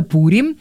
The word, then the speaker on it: Пурим: Да, надеюсь, что сегодня мы совместим приятное с полезным, потому что Пурим, или, кстати, вот давайте сразу, да, Пурим, как ну, вот... от слова «пур» значит «Пурим». – Пурим, да? Хорошо, ладно, что... постараемся тогда, значит, говорить правильно, Пурим.